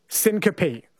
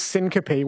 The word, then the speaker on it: syncope: Syncope. So the word is syncope, which is spelt SYN.